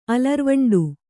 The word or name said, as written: ಅಲರ್ವಂಡು